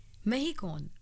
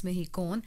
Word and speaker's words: MHECON: Mehicon, which is spelled as MHECON 2026 Mehicon,